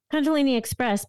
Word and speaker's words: Kundalini Express: Did I say that right, Holly? I know I always mess up the words, but... – Kundalini Express, but it's funny. In the song, they say Express Kundalini. You're close enough.